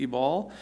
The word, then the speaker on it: Ebal: Therefore, it shall be, when you have crossed over the Jordan, that on Mount Ebal, or Ebal, if you know in the how to pronounce it, that B is a V actually, Ebal,